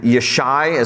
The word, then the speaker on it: Yishai: Now, Jesse in Hebrew is Yishai. There is no letter J in the Hebrew alphabet. So it's spelled with a Y, and Yishai is how you would say his name.